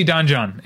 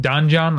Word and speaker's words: Don Jon: Have you been saying it Don Jon like I have, or Don Jon, which is probably the right way to say it? – I think I probably emphasize Jon. – Yeah, I think that's the way you're supposed to do it because his name is Jon and it's a play on Don Juan.